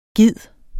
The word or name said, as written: gid